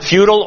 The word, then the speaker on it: futile: F-U-T-I-L-E. You can pronounce it futile or futile.